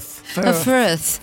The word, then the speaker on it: Firth: Firth. – Firth. – Your pronunciation Firth. Firth. – Is perfect. Firth.